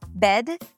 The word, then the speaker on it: bed: Today we're going to talk about the vowel pair bed, bad, right? Eh, ah. As you can hear, these are two different vowels, but they're often pronounced the same by non-native speakers.